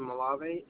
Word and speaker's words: Malave: See, so Brendan, B-R-E-N-D-A-N, last name Malave, M-A-L-A-V as in Victor, E.